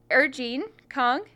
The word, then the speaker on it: Er-Gene Kahng: Um, Er-Gene Kahng.